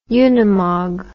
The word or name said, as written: unimog